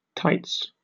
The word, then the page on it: tights: British English